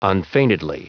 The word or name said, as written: unfeignedly